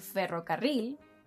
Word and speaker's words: ferrocarril: So, to pronounce words like ferrocarril, carrera, tierra, perro, barril, simply think of trilling the R sound.